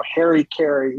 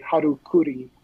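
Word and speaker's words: harakiri: I was saying the really poor pronunciation of this word, the American, you know, Harry Carey, what we call, but it's actually Harukuri.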